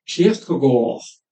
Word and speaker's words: Kierkegaard: J'ai lu tout récemment que la juste prononciation, ce serait Kierkegaard. Mais j'ai l'habitude, comme on fait en France en général, de dire Kierkegaard, évidemment.